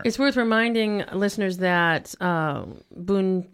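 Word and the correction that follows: Buñuel: no, suddenly I'm not, saying it properly. – Boonwell. Boonwell, Uh, is a contemporary and friend of Salvador Dali and yeah.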